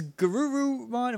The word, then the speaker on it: Garurumon: Is Garurumon, am I saying that right? – Yeah.